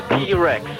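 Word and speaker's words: T. Rex: T-Rex.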